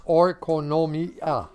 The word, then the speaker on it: oikonomia: It's pronounced oikonomia from G3623, and it means administration, such as an administration of a household or estate.